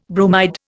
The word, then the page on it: bromide: Indian